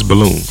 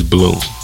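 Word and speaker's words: Buhloone: It's Balloon. – Balloon State of Mind? – Yeah. It's spelled funny, but it's Balloon.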